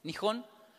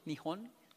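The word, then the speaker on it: Nihon (日本): Nihon, así se pronuncia, Nihon, tiene trazos curvos el de abajo.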